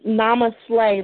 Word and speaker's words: Namaste: Well, Namaste, like I slay. Namaste _ Goddess on Instagram.